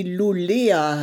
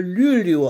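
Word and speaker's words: Luleå: Und zwar war ich fast am Nordpol in einer Stadt, die Lulea heißt, aber Lülio ausgesprochen wird.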